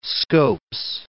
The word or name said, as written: Scopes